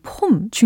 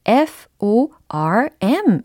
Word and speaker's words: form: 뭐 골프를 치시는 분들 아니면은 뭐 여러 가지 수영을 하신다거나 뭐 볼링을 하시는 분들 이런 폼 중요시 여기잖아요. 자세라는 의미로도 쓰일 수가 있는 단어인데요. 철자는 F O R M 이라는 철자입니다. 발음이 다르겠죠. 폼이 아니라 form form form 이렇게 해 주셔야 되겠습니다.